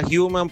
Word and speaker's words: Human: Sí, se pronuncia human porque la H en inglés.